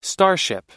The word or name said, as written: starship